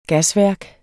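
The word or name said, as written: GASVÆRKER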